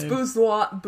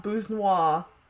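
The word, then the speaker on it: Busnois: It's Bousnois. Bousnois. – Bousnois. – Bus noise. Bousnois. – I've not heard a bus noise. – Beep, beep. – No, I have not heard of Antoine Bousnois.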